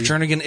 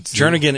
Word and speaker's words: Jernigan: Dr. Jernigan is important, and that's also in your address. – Man, look, you said it right that time. – Jernigan is in your address.